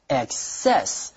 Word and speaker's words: excess: When we use it as a noun, the stress is on the second syllable, excess.